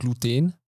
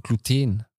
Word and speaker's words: Gluten: das heißt aber Gluten. Mhm. Also auf dem Teen ist, es, äh, ist die Betonung. – Nicht ah, auf dem ah, Glu, ja. sondern auf dem mhm. Teen. Gluten.